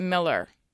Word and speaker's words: Moeller: And Brett Jones and Moeller, but it, he pronounced it Miller, but it was spelled like Moeller.